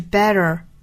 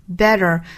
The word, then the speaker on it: better: 那美式发法是 better, better, 也就是中间的这个 t 的发音呢，是发 flap t, 发这个闪音 r, 然后尾部呢又有个卷舌，所以说 r, better, better.